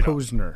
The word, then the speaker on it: Posner: It is Mike Posner. Mike, thank you for joining us. – Posner. You said it wrong. – Posner. I wrote it down, too. I actually wrote it down. Mike Posner. P-O-H-Z-N-E-R.